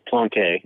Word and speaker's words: Plonk: It's pronounced Plonk. – Oh, I'm sorry. Plonk. – Is Plonk.